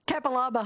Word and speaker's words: Capalaba: We've got Karen from Capalabar on the line, or Capalaba, as it's known. Hi, Karen. That tickled Karen, that one. Capalaba. – Well, I'm not a honey. – Capalaba honey.